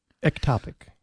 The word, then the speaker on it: ectopic: Ectopic? – Ectopic. – Ectopic. So you have a different emphasis there.